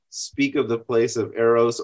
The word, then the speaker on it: Eros: Is that, I'm saying that right? – Eros. I say Eros. – Okay. – You can say Eros.